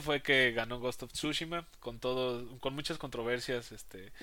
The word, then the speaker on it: Tsushima: sinceramente he escuchado que lo pronuncian de las dos maneras. En, eh, inglés en inglés se pronuncia Tsushima, en español Tsushima.